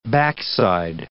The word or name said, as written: backside